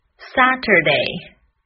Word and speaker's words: Saturday: 第三个 Saturday，注意第二个音节的末尾要卷舌，重读在第一个音节上。